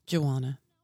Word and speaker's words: Juwana: Juwana. – So it kind of sounds like Joanna, but Kind of. Yeah. – But it's actually really said the way it's spelled, J-U-W-A-N-A. Uh-huh. So it's Juwana.